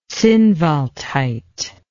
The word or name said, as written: zinnwaldite